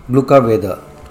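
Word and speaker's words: gluggaveður: The word of the week is an Icelandic word pronounced glukka weather.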